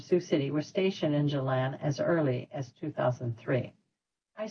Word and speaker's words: Gjilan: And the name of that city is spelled G-J-I-L-A-N, Gjilan.